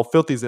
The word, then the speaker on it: F1lthy: Like it was the project with Lucki and uh F1lthy, but it's like it's eh, but it's spelled F one T H Y. Um. Oh, Filthy's oh he's a producer.